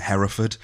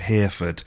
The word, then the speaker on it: Hereford: It's the bit where De Niro is talking to Sean Bean's character, and they're talking about an SAS training ground in Hereford, but Robert De Niro pronounces it as Hereford. – Oh. – And it annoys me that he gets it wrong,